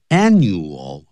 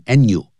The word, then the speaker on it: annual: Annual 发音的时候也不能偷懒，念成 annual， annual 就不对。 Annual 那个 L 的音呢在尾巴的时候念成 O，舌尖翘起来。 Annual， annual， girl，女孩子不要念 go， go，叫 girl 啊，舌尖翘起来，像那哦，像欧洲的欧，舌尖翘起来。Usually held from January until March.